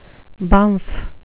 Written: bumf

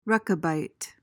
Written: Rechabite

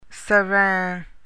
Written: serein

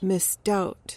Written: misdoubt

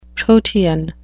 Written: protean

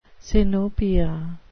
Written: sinopia

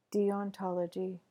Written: deontology

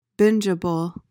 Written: bingeable

